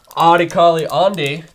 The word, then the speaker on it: Adekale Ande: We have Adekale Ande.